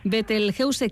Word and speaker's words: Betelgeuse: Bueno, pues en este caso ese homenaje, aunque, bueno, pues lo pronunciamos tal cual se escribe para que no haya lugar a errores. Asociación Betelgeuse, ¿que nace cuándo, Nacho?